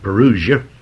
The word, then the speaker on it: parousia: The Bible calls this the parousia, P-A-R-O-U-S-I-A, a Greek word that we bring right over into the English, often pronounced parousia, but it's a Greek word. It should be pronounced parousia.